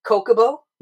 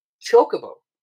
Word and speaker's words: Chocobo: But then again, it's like, it's the divide in Final Fantasy fans, where it's some people say Kokobo, and some people say Chocobo. – You pronounce the H.